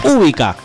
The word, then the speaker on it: Ubica: Ubica, con el acento en la U.